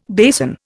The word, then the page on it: basin: Indian